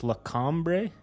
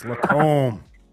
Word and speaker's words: Lacombe: So let's welcome Cassandra Page coming all the way how do you pronounce this? Lacombre, Alberta. – Yeah, is that right? That's right. I'm just joking. It's Lacombe.